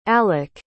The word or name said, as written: alok